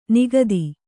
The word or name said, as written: ನಿಖಾತ